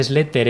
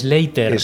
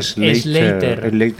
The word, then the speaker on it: Sleater: Que no se dice Slater, es Slater. – Slater.